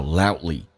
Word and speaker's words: loudly: 来看一下 Yell 这个 L，舌尖要抬起来，靠向你的上齿龈，也就是上牙齿后背一点点，叫 yell loudly，英式发音叫 loudly，美式发音叫 loudly，那个 D 可以不用发出来，因为 D 跟 L 两个辅音在美语当中只用发后面一个，前面去掉气流阻塞一下就变成 loudly loudly。